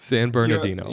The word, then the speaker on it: San Bernardino: There's a whole syllable in there that you missed. – Bernardino? Yeah. What is it? – San Bernardino.